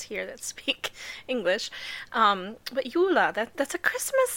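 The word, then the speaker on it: Juhla: That was Yula.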